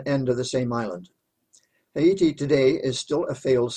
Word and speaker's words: Haiti: Most people say Haiti, I use the Spanish pronunciation. On the western end of the island of Hispaniola in the Caribbean Sea. The interesting thing about Haiti